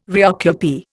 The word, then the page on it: reoccupy: Indian